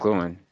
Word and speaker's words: McLuhan: And you basically got that from, correct me if I'm wrong in pronouncing his name, but his name is Marshall McLuhan. Is that correct? – McLuhan. Yep, that's him.